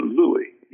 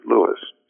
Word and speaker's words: Louis: But if they asked, he would always tell them that he was not a Creole, and so you didn't pronounce it Louis, you pronounced it Louis.